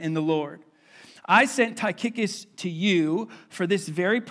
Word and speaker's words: Tychicus: His name is Tychicus, and I promise you that is how you pronounce it, and if it's not, then you're wrong and I'm right. Na-na-na-na-boo-boo, all right? Tychicus will tell you, he's also dead.